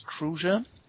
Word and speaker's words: Kruse: And I guess the German pronunciation is Kruse.